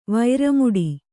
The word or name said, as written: ವೈರಮುಡಿ